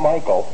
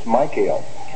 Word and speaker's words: Michael: It's not Michael, it's Michael.